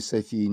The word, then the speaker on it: София: Для тех, кто не знаком с греческим языком, заметим, что в древнегреческом языке слово «София» – «мудрость», «премудрость» – произносилось с ударением на предпоследнем слоге, в то время как современное женское имя «Софья» произносится с ударением на первом слоге. В современных философских рассуждениях ударение в слове «София» необходимо ставить на предпоследнем слоге – по древнегреческому образцу.